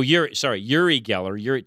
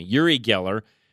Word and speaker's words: Uri Geller: Oh, how do you say it? Oh, Uri Geller. Charlie's correcting me. Uri Geller, the professed mentalist known for his serial abuse of flatware.